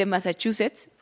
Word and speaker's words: Massachusetts: Él dice, no sé si sea una repentina onda de calor o qué, pero cada que le opina, o dice Massachusetts, y ahora sí lo digo bien, maldito sea, siento cosas de señor abejo romántico.